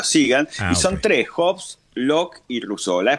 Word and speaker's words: Hobbes: Se escribe H-O-B-E-E-S, Hobbes.